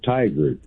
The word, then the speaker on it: Tigard: it almost sounds like tiger, but it's tiger. It's like T-I-G-A-R-D.